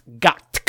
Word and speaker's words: GOTQ: We have the Twitter at Game of the Queer, and the abbreviation for it is GOTQ, which we agreed is pronounced Gotk. – Gotk. Yes, Gotk.